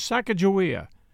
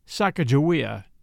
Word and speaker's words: Sacagawea: Her name, as we commonly pronounce it today, was Sacagawea, probably pronounced Sacagawea in reality, as many historians have determined, but opinions are still divided.